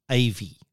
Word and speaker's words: AIVI: It's the Osmo T8, and they pronounce it AVI, which is A-I-V-I, artificial intelligence, visual interpretation.